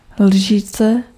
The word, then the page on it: cuiller: /kɥi.jɛʁ/